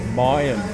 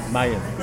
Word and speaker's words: Maya: So, we were pulled up for mispronouncing the Mayan people, or Maya. – In Mayans. – And we were calling Mayans. Mayans, apparently.